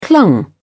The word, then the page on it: clung: British English